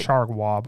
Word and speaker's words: Chargois: Am I still saying the name right? I'm just not getting my French right. – Chargois, but it doesn't matter. It's just, you're doing great.